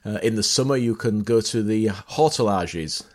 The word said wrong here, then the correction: hortillonnages: I always say this wrong. Hortillonnages. That sounds better.